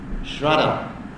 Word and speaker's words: shraddha: So when Krishna uses the word surrender, it's very similar to the word shraddha. Shraddha, pronounce it properly.